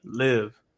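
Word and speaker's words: Liv.e: Her name is, I think it's, it's pronounced Live. It's spelled L I V uh, uh, period E, Live.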